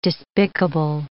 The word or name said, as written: despicable